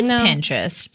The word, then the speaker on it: Pinterest: So, Pinterest. – Yes. – Mm-hmm. Pinterest. – It's like interest with a P.